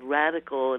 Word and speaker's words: Radicle: And her site is called Radical, and it's spelled RADICLE, Radical Health.